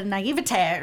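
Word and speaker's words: naïveté: There's such a smugness to him in there, and such a naivete. Or is that how you say it? Naivete? – Naivete? Sounds yeah. – right. – Yeah, okay.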